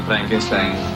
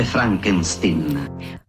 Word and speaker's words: Frankenstein: Io avrei una domanda, dottor Frankenstein. – Si legge Frankenstein.